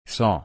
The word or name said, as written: saw